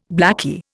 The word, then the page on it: blakey: Indian